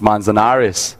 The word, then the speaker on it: Manzanares: Not a lot of people pronounce my name well at the first time, so congrats. He's been looking at Spanish pronunciations, I guess, working on that all week to pronounce Manzanares.